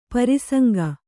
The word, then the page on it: ಪ್ರಸಂಗಿ: prasaŋgi